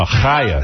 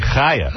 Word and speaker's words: Chaya: It's pronounced, it's spelled Kaya. – Okay. – But C-H. – It's really, so, you know, never. – No, Chaya. – The same way. – It's really Chaya.